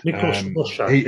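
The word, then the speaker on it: Miklós Rózsa: Miklós Rozsa, yeah.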